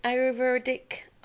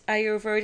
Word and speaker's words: Ayurvedic: That's, is it Ayurvedic? I'm not pronouncing that right. It's Ayurvedic.